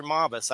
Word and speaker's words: Mabus: How do you say it personally? Mabus or Mabus? I hear it both ways. – You know, it's interesting because it's Mabus,